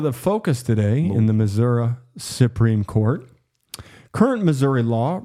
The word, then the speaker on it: Missouri: That's how you say it, Missouri. That's how they say it. – I can never remember. – They say Missouri. They don't say Missouri.